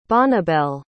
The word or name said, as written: Bonnibel